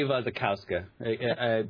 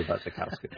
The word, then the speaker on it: Wasikowska: Well, I'm going to say Wasikowska, because I've taken to pronouncing W's as V's now.